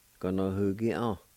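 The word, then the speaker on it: ganohugia: It's pronounced like this. Ga-no-hu-ki-an. Ga-no-hu-ki-an. Ga-no-hu-ki-an.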